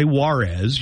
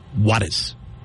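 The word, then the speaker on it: Juárez: You don't say Juarez. You're supposed to say Juarez. – Juarez. – Juarez. – Do you know how that was explained to me? Pretend it's spelled W-A-T-T-I-S.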